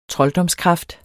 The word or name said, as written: trolddomskraft